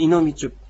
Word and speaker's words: いのみちゅっ: あの先ほど冒頭で問えたんでちゅっという、その 1月こう言いますという説明がありましたが、別名でこう、いのみちゅっという言い方をしたのだというようなことを、あの、萱野茂先生がおっしゃっています。で、このいのみちゅっというのは、ちょっと発音、ちゅっというのは発音しづらいですが、練習してみましょう。